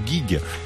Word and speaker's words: gigs: On va commencer à discuter avec eux, puisque tout à l'heure, je vous l'ai dit à la française, j'ai dit 2 gigs, mais bon, ça se dit 2 gigs.